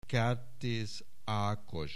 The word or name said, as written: Kertész Ákos